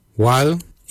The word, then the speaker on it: waly: Jup is spelled D-J-O-B. Jup. Jup is your kidney. Lungs, we say wal. Wal is your lungs. Wal is spelled W-A-L-Y.